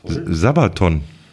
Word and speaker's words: Sabaton: Sabaton. Werden die so ausgesprochen oder geschrieben? – Geschrieben als auch ausgesprochen. – Sabaton.